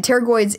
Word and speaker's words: pterygoids: And pterygoids is spelled P T E R Y G O I D S. So it's sort of like pterodactyl. If you've ever seen it on paper, that's how you say it.